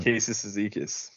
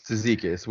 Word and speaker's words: Cizikas: Casey Cizikas. – Cizikas,